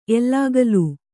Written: ಎಲ್ಲಾಗಲೂ

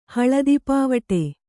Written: ಹಳದಿಪಾವಟೆ

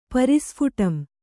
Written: ಪರಿಸ್ಫುಟಂ

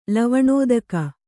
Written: ಲವಣೋದಕ

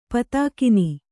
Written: ಪದಗಾಣ್

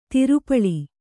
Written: ದೋರಪಾಲ

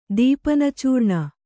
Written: ದೀಪನಚೂರ್ಣ

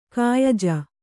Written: ಕಾಯಜ

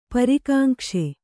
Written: ಪರಿಕಾಂಕ್ಷೆ

